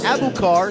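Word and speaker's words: Aboubacar: It's A-B-O-U-B-A-C-A-R, pronounced Abukar, and Sis is C-I-S-S-E, pronounced Sis.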